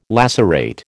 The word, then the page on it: lacerate: American English